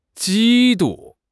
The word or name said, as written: 嫉妒